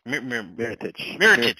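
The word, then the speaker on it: Mirotić: Mirotic.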